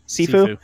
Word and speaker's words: Sifu: I mean, if you just look at newest game that came out, Sifu, how do you say Sifu? – That? – Sifu, yeah.